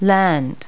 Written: land

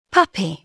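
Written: puppy

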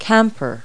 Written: camper

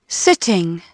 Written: sitting

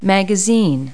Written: magazine

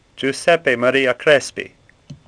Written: Crespi

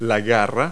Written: talon

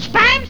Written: Spam

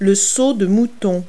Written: overpass

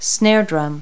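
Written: snare drum

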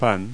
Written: bread

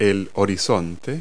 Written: horizon

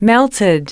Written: melted

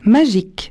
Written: magique

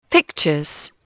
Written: pictures

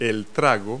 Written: tragus

